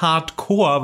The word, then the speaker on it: Corps: aber es heißt Hardcore, weil das Wort Corps äh, ohne e am Ende wird Core ausgesprochen, deswegen heißt es auch Blastcore.